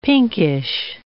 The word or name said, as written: pinkish